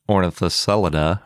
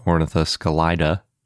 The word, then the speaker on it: Ornithoscelida: It started with Matt Barron discussing Ornithocelida, or Ornithoscelida, depending on how you want to pronounce it.